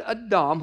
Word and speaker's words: Adam: He created small a, Adam, spelled the same as Adam, but pronounced Adam in the Hebrew. He created Adam, which means humanity.